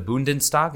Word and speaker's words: Bundestag: Oh, so as I understand it, there is a growing coalition in the Bundestag. Am I saying that correctly? Yes.